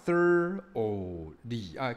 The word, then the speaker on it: thoroughly: 那下面这个单字是仔细的，就是洗手要洗得够仔细，这个单字叫 thoroughly。各位这个 gh 都不发音了，所以你会听到 o 的声音而已哦。